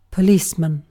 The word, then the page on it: policeman: British English